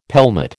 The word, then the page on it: pelmet: American English